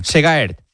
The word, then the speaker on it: Segaert: Y en ciclismo, el belga Alex Segaert, ¿lo he dicho bien? – Segaert. – Alex Segaert se ha... ¿Cómo? – Parecido. – Lo he dicho parecido, sí.